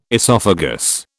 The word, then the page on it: esophagus: British English